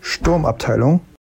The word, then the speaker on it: Sturmabteilung: Sturmabteilung.